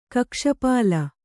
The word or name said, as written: ಕಕ್ಷಪಾಳ